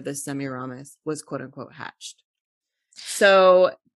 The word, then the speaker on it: Semiramis: Semiramis.